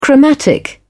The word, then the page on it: chromatic: British English